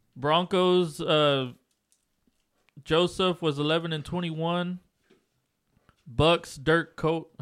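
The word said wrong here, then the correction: Koetter: How do you say that last name? – Dirk Cutter.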